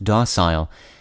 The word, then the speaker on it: docile: The word of the day for November 9th is docile, also sometimes pronounced docile, and spelled D-O-C-I-L-E.